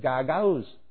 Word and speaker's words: Gagauz: At the time, I did not know how to say it. It was G-A-G-A-U-Z, so Gagauz or something like that was how I was saying it. And I learned later that you do pronounce it Gagauz.